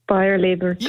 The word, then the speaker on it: Bayer Leverkusen: Were you going for Bayer Leverkusen? – Yes. – What was that? – Bayer Leverkusen.